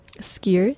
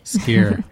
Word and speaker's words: skyr: Skier. – Skier.